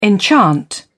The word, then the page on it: enchant: British English